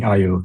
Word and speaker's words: EYEO: So it's eye opening IO. That's where it's coming from. Yeah. – So it's an English joke. – It's an English gimmick. Yeah. – It's spelled EYEO for people listening to this.